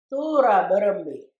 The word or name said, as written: Thoowoorra berrembi